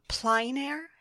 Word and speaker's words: plein air: Well, let's talk about a little bit about your plein air.